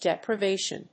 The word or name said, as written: deprivation